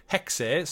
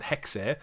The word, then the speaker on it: Hexe: We got Assassin's Creed codename Hexe. So we were saying Hex, but it literally, they do pronounce the E on it, Hexe.